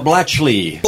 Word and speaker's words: Blatchley: Blackley is here. I am saying that right, yeah? – Blatchley. – Blatchley. – Yeah, the K is silent.